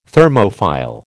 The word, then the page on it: Thermophile: American English